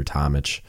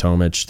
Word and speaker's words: Tomich: You might hear people pronounce it Peter Tomich. My understanding is it's pronounced Tomich.